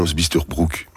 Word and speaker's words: Bisterbrook: La rencontre est organisée avec Alliance des Marais, Bister Break, Non, Bisterbrook, pardon, s'écrit vraiment fort petit.